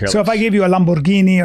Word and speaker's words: Lamborghini: He just bought a Lamborghini boat, I believe. – Yeah, it's pronounced Lamborghini, first of all. – Oh, pardon me? – Yeah, Lamborghini.